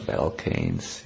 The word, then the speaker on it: Balkan: How do you pronounce it, please? – Balkan, yes.